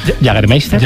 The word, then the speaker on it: Jägermeister: Jägermeister.